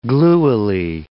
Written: gluily

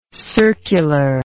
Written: circularness

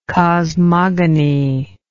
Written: cosmogonist

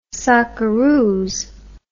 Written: Socceroos

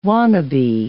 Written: wannabe

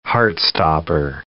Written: heart-stopper

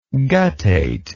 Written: guttate

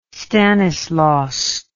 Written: stanislaus

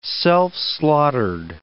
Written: self-slaughtered

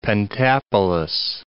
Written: Pentapolis

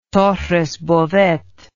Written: torres bodet

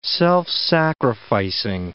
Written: self-sacrificing

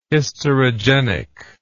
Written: hysterogenic

